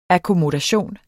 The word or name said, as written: akkommodation